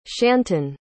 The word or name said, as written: shanton